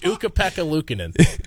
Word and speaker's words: Ukko-Pekka Luukkonen: I definitely butchered his name, too. – Say it again. – It's Ukko-Pekka Luukkonen. – Ukko-Pekka Luukkonen. – Luukkonen. – Ukko-Pekka Luukkonen. – It's a hell of a name. – Is that actually how it's pronounced? – I might be off on a syllable.